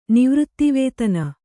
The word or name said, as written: ನಿವೃತ್ತಿವೇತನ